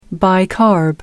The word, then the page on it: bicarb: American English